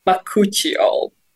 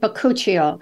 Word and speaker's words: Bakuchiol: Bakuchiol. – Oh, Bakuchiol.